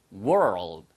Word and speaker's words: world: world 발음을 조금 어려워하시는 분들이 계신데요. word는 단어란 뜻이고요. were까지는 똑같아요. 근데 l을 한번 튕겨주세요. 혀 끝을 튕겨서 world. world 이런 식으로 발음하시면 이게 바로 세계란 뜻입니다.